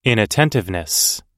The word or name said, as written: inattentiveness